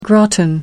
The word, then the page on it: gratin: American English